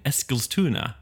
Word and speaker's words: Eskilstuna: No, the hole in this shirt happened at a party in Eskilstuna.